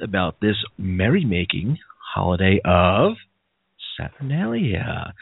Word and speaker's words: Saturnalia: So they're looking for a holiday to compete with the Roman holiday of, I always pronounce this wrong, but I want to say Saturnalia. – Saturnalia.